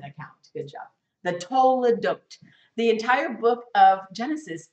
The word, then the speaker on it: toledot: It's the word toledot. T-O-L-E-D-O-T. Or sound it out better for yourself. It doesn't matter. We'll learn the Hebrew later. But the word is pronounced toledot.